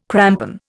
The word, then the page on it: crampon: Indian